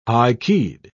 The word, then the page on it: high-keyed: British English